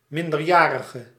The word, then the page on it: minor: /maɪnə/